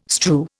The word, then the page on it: strew: Indian